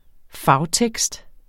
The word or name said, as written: fagtekst